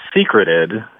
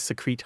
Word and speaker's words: secrete: So it is always secreted. It's secrete.